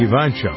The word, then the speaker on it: Evancho: This song is from Jackie Evancho. Is that how you pronounce her name? – Mm-hmm. Yep.